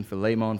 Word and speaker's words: Philemon: I've heard Philemon my whole life. So I've been repeating over and over and over again the Greek pronunciation, Philemon, Philemon.